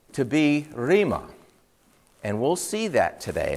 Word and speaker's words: rhema: The word is spelled R-H-E-M-A, rhema, means word.